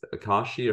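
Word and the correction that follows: açaí: or how do you say it again? – Asahi. – Asahi.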